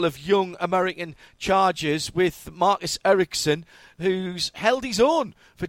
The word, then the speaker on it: Ericsson: Marcus Ericsson for Husky Chocolate Chip Ganassi Racing.